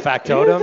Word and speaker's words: Factotum: Factotum? Is that how you say it? – Yeah, Factotum.